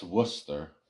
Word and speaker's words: Worcester: I know War, you know, by Springfield, Worcester, that's Worcester. That's how you say it.